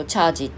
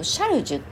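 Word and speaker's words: charge: なぜそれをチャージって読まないかっていうとフランス語でチャージって同じあの、えー、字面で書いても、えーと、シャルジュって読むっていうのをねたまたまその当時見つけてでそれで、えーと、シャルマって読むんですね。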